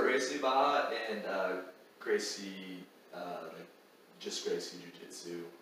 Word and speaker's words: Barra: And I think I asked you and you were saying that particular one that you're involved with, Gracie Barra? – Baja. – Baja, Gracie Baja. – R's. – What is it? – They don't pronounce R's in Portuguese.